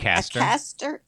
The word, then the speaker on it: Acaster: Acaster.